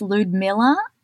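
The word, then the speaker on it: Lyudmila: In his personal life, he married a woman called, I think it's pronounced Ludmilla, but it starts with an L-Y.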